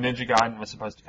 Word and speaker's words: Ninja Gaiden: It's pronounced Ninja Gaiden.